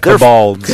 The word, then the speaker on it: Kobolds: Now, in the Sunless Citadel, the players traverse a multi-level dungeon populated by two warring factions, the Goblins and the Kobolds? – Well, how do you pronounce it? – Just checking. Hey, man. All right. – Kobolds. – the Kobolds. The Kobolds, okay.